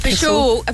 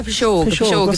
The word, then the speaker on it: pishogue: It's great to see some Irish words still in use today in the Irish language, phrases such as, that's, how do you pronounce that? That's only a pishogue. A pishogue is an Irish word. – Pishogue. There are plenty more.